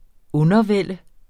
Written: undervælde

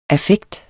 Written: affekt

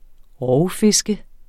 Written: rovfisk

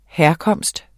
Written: herkomst

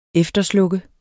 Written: efterslukke

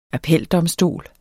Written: appeldomstol

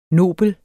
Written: nobel

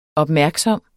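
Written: OPMÆRKSOMST

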